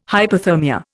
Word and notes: hyperthermia: Indian

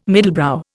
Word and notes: middlebrow: Indian

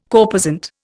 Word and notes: corposant: Indian

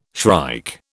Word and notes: shrike: British English

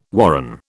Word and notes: warren: British English

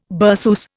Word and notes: berceuse: Indian

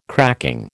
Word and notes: cracking: American English

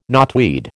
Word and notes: knotweed: American English

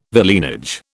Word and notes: villeinage: British English

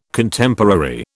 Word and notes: Contemporary: British English

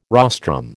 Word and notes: rostrum: American English

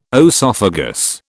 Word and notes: oesophagus: British English

